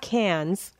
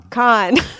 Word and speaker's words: Cannes: It's not Cannes. – Con. – Why do they spell it like Cannes if they pronounce it con? – Yeah, well...